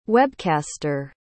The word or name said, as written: webcaster